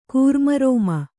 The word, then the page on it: ಘರ್ಮೋರ್ಮಿ: gharmōrmi